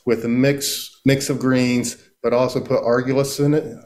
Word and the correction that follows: arugula: Argillus, is that how you pronounce it? – Arugula. – Arugula.